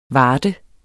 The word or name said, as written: varte